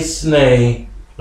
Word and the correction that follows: Chesney: Ok, Get Along Kenny Chesney.